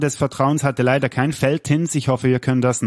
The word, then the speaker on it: Veltins: Oder wie spricht man das Veltins aus? – Veltins.